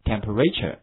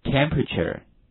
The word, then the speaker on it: temperature: 气温这个词的话，一定不要把重音读成 temperature，那是不对的哈，跟我读一下 temperature， temperature。